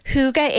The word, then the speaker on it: hygge: But for anyone listening and they mightn't know what hygge is, can you tell us a bit about it? – Yeah, and first of all, your pronunciation is perfect. – Thank you. – That's how we say it.